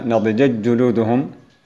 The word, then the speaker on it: نضجت جلودهم: في هذه الكلمة نضجت جلودهم ننطق بثلاث جيمات، الجيم الأول الجيم العادي وغير مضغم، والتاء تضغم في الجيم بعدها، ومعلوم أن المضغم ينطق في حال إضغامه بحرف واحد مشدد اللي هو الحرف الأخير. فنضجت جلودهم تنطق بالجيم الأولى مفتوحة وبالثانية مثقلة أي مشددة، وتختفي التاء.